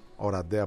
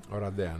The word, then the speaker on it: Οραντέα: Ρουμανία-Ελλάδα στην πόλη Οράντεα. Οραντέα, όπως προφέρεται. – Οραντέα, ναι.